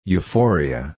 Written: euphoria

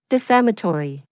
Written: defamatory

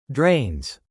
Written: drains

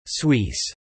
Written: Suisse

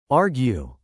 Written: argue

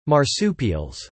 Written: marsupials

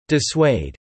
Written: dissuade

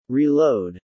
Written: Reload

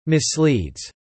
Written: misleads

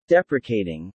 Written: deprecating

